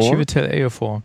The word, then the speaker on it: Chiwetel Ejiofor: Wen ich noch gut fand, war, ich kann seinen Namen immer noch nicht aussprechen, Chiwetel Ejiofor. – Chiwetel Ejiofor, genau.